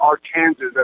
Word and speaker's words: Arkansas: Somewhere in Arkansas, or Arkansas, as I believe they say it. – Oh, okay. – That is how it's pronounced, actually.